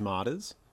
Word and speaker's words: Matanis: I say it like a lazy middle American, which is modest, but I answered to Philip.